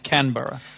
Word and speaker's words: Canberra: Well, how do you say it? – Canberra. – The capital of Australia. – It's a capital of Australia. – Because if you get it wrong, people make fun of you. The way to deal with it is like, no, sorry, dude, you're saying it wrong. The way to say it is Canberra.